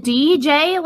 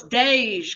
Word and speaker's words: Dej: Is it DJ Loaf? Because there the E is lowercase. – Oh, it's Dej, because her real name is Deja Monet Trimble. – Okay, Dej Loaf.